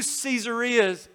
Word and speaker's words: Caesarea: There are two Caesareas in the Bible. And I pronounce it Caesarea because they're named after Caesar Augustus.